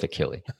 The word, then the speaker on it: Achille: Is it Achille? – Sure. – Achille, Oklahoma. – It's kind of like Achilles Hill, but it doesn't have an S on the end.